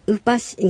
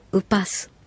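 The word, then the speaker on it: ウパㇱ: なお、この小さいシは、スという発音になることもあります。ウパシがウパス。イルシカはイルスカ。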